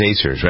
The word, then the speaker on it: Acer: I always wonder if I'm pronouncing that correctly, Kenny. It is Acers, right? A-C-E-R.